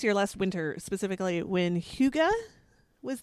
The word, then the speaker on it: hygge: So, but spell it because I did not know that's how it was pronounced. And so I never would recognize it. – It's H-Y-G-G-E.